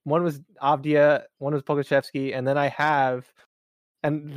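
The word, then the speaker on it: Pokusevski: And I'm also happy that I pronounced his name correctly on this podcast. – Pokushevsky? – Yeah, Pokushevsky.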